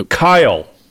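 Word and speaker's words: X Æ A-12: I've been told, I did some investigation, apparently that name is actually supposed to be pronounced, do you know this? – No. – Kyle.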